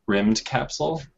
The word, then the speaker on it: Rymdkapsel: It's, I think it's pronounced rimmed capsule. It's spelled R Y M D K A P S E L, and it's kind of a uh, RTS game, I guess.